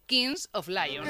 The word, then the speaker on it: Kings of Leon: Bueno, y luego en el Mad Cool, claro, justo actuaba en el momento del partido España-Bélgica el grupo estadounidense Kings of Leon.